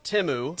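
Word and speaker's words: Temu: I learned that Timu is actually pronounced Timu.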